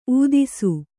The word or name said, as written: ಊದಿಸು